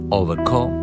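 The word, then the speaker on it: Overgaauw: Εδώ το ντεμπούτο άλμπουμ με τίτλο Don't Disturb στην Ολλανδική CBS του Wim Overco. Έτσι προφέρεται να το επίθετο του γράφεται Over, όπως το ξανά, και μετά G-A-A-U-W.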